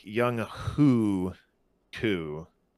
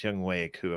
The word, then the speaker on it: Younghoe Koo: It looks like it's Young Hoo Koo, but it's Young Wei Koo apparently.